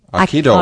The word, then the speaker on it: Aikido: Aikido.